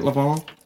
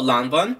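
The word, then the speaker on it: Lanvin: Is that how you say it? Lavon? – Oh, Lanvon? – Fuck! God damn it. Yeah, that.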